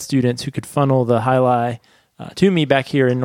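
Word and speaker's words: Jai Alai: It looks like JLA, but it's pronounced Hyalai.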